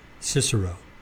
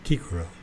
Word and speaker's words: Cicero: Cicero. I read somewhere that that's properly pronounced Kikero.